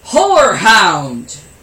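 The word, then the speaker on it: horehound: Whorehound! And no, I'm not saying the H-O, the W-H-O-R-E. It's actually called H-O-R-E, but it's still pronounced the same way.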